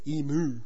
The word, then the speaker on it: emu: I put emu in this list because I've heard Americans pronounce it emu. Instead, listen to the correct way, emu. If you think about the first part as being spelled E-E-M and the second part Y-O-U as in you, you'll have no worries.